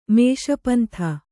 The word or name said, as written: ಮೇಷಪಂಥ